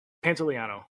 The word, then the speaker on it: Pantoliano: Joe Pantoliano. Did I say that right? – Close. Yeah, it was good. – Pantoliano.